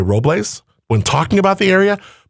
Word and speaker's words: Robles: It's supposed to be Robles. So if you say it Robles, yeah, you're doing it right.